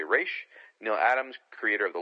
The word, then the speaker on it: Ra's: Both Julie and Denny pronounced it Raish, so Raishagul out of Denny O'Neil's mouth.